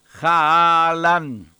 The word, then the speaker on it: Haaland: Es Haaland, marcando bien la A. Es Haaland.